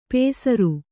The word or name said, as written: ಪೇಸರು